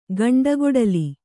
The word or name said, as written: ಗಂಡುಕೊಡಲಿ